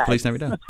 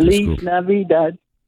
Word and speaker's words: Feliz Navidad: Feliz Navidad. – There you go. Yes, I should remember that. My kid today. – Feliz Navidad.